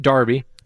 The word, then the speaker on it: D'Arby: So that's, Terrence Trent D'Arby. I believe it's pronounced Darby.